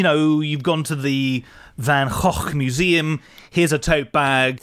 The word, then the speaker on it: Van Gogh: Or Van Gogh, as a lot of Brits say, but apparently yes, the proper way to say it is Van Gogh.